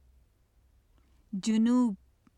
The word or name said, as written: junub